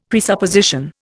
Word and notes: presupposition: Indian

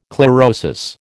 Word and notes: chlorosis: American English

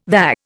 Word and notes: VA: Indian

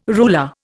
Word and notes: ruler: Indian

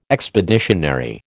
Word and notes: expeditionary: American English

